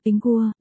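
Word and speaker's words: Pingguo (苹果): quả táo được phát âm là Pingguo, đồng âm với chữ bình trong đêm bình an, Ping gần nhè.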